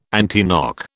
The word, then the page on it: antiknock: British English